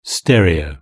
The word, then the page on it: stereo: British English